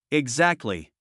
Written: exactly

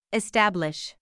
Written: establish

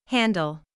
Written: handle

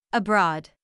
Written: abroad

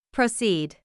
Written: proceed

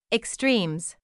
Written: extremes

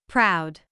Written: proud

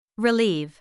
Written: relieve

